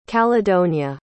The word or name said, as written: caledonia